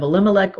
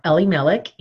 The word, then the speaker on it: Elimelech: Now Naomi had a relative of her husband's, a worthy man of the clan of Elimelech or Elimelech, you can pronounce it either way, whose name was Boaz.